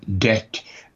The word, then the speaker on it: debt: You know, there are these idiots, he says, who pronounce debt as debt, whereas everybody knows it should be debt, you see. – Because there's a B in it. – It's because there's a B in the spelling.